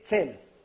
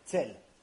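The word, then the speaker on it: Tzel (צל): How do you say shadow? – Tzel. Tzel. Tzadik Lamed. Like T-Z-E-L.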